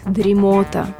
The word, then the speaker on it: дремота: В общем, одолевает дремота. Казалось бы, слово обычное, а так и тянет сказать «дремота», что, конечно же, неправильно. Причем словари отдельно помечают, что ударение на последнем слоге в слове «дремота» является грубейшей ошибкой.